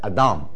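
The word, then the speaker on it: Adam (אָדָם): Adam 看到 Adam 这个字吗？啊，在我们刚才所读的 Hesed 跟 Adam 啊，看到这个字吗？Adam 那你发现这个尾音节是 close syllable，这个母音应该是什么？短母音，对不对？但是它这是一个长母音来的。啊，它的读法是读 Adam，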